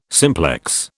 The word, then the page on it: simplex: British English